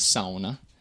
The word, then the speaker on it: sauna: You know, you'll be corrected by a lot of people correcting you that the proper way to say the Finnish word sauna is sauna, something like that. That's the closest I can do with my poor Finnish.